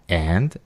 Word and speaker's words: and: En esta parte, un tip de pronunciación es que al pronunciar la palabra and, lo hagas con un ligero sonido E en vez de una A, y lo pronuncies como and.